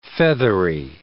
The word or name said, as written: feathery